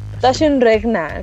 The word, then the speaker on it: Taschenrechner: Pero, bueno, pon Taschenrechner. Taschenrechner. Acuérdate de tus clases de alemán. – ¿Taschenrechner? Ah, sí, Taschenrechner. Cierto, ¿verdad? Que R es... Se pronuncia como A al exactamente. – Final.